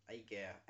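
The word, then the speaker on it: Aegir: Eiger. A-E-G-I-R. I think I'm saying that right.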